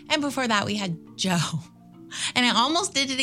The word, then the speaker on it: Djo: It's DJO, but it's just Joe. It's just pronounced Joe.